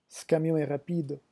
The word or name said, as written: ce camion